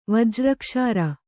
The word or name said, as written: ವಜ್ರಕ್ಷಾರ